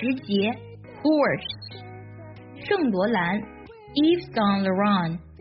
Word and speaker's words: Yves Saint Laurent: 这个牌子的全称呢，它读作 Yves Saint Laurent Yves Saint Laurent。